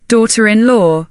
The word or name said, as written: daughter-in-law